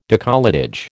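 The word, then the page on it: décolletage: American English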